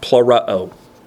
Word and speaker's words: pleroo: And it's pronounced plurao. Like I said, emphasis on the uh oh.